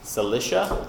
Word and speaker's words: Cilicia: and the people from Cilicia. Is that how you pronounce that? I've never been sure. – That's how we say it in English.